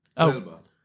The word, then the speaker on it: Huelva: Huelva? – Huelva. – Try and pronounce it. – I wanted to have some accuracy. – Because you don't pronounce the H in... Spanish.